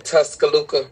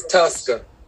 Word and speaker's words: Tuscaloosa: And to the families, who lost loved ones at another senseless gun violence in Tuscaloosa at the medical center. I pronounced that wrong. I apologize. Tuscaloosa.